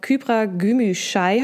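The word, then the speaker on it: Kübra Gümüşay: Ich freue mich jetzt, ich bin sehr gespannt auf Kybra Gümüşay.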